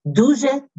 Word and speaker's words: дуже: D-Y-A, дякую, Y-U. Дякую. – Дякую. – That's thank you. Thank you very much. You would add дуже, two syllables. Дуже дякую, which is very much thank you, but the orders, but it's thank you very much.